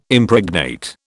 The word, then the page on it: Impregnate: British English